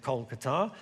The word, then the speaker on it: Kolkata: Nowadays we call it Kolkata, to give it its Bengali pronunciation.